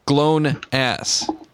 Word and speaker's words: GLONASS: Is that GLONASS? – That's right. You said it correctly.